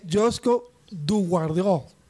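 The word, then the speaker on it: Joško Gvardiol: Por ejemplo, ¿cómo se dice Joseph no, Josko Guardiol, un jugador de, Croacia. Esta gente de ESPN se pegó la empelotada en su lengua. y es Josko Guardiol, dicen los croatas.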